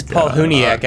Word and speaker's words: Palahniuk: Did I pronounce his name right? – I think so. Soft teeth. Okay. – I think so. – Chiseled abs and soft teeth. – Oh, man. Oh, it's Paul Huniak, actually.